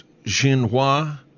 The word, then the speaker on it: Xinhua: I think it's pronounced Xinhua News Agency, had really interesting things to say.